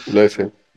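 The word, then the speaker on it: Lecce: Después, otro, partido interesante, el eh, Leche, Leche, o sea, Leche, no sé cómo se pronuncia. – Leche. – Ay, Dios mío, Leche contra Milan el 2x2. – Leche, sí, sí, sí, no, lo hiciste bien.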